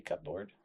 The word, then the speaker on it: cupboard: Do you pronounce the P, cupboard? – Cupboard? Cupboard? – Cupboard? – Yeah, just cupboard.